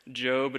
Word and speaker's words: Job: So if you have a Bible and you want to get that out, it is right before the Psalms, spelled like Job, pronounced like Job.